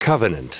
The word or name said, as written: covenant